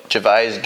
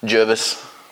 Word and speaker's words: Gervais: Oh yeah, Gervais, Gervais. – This is good. – Jervis, Gervais.